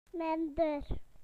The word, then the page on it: less: British English